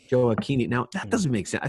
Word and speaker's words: Gioacchini: Well, the CH is a hard C. – So, but this one GIOACCHINI. – Yeah, but if it's followed by an H, it's the hard C. Gioacchini.